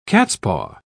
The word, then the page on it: cat's-paw: British English